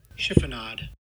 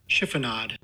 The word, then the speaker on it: chiffonade: Chiffonade. – Barely even. – Chiffonade. – Chiffonade? – Chiffonade.